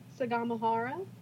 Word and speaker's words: Sagamihara: Back in September of 2019, I was staying in the town of I'm going to butcher this Sag, Sag Sagamihara, Sagamihara. Could be worse. – In the Kanagawa prefecture. – Hmm. Could be worse the way that you described that. I think that was a pretty good pronunciation.